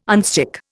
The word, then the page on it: unstick: Indian